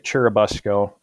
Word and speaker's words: Churubusco: I think I'm pronouncing that right. – Yeah, that's right, Churubusco. So I've been at Faith for just a little over 4 years now, and COVID came right in the midst of my ministry.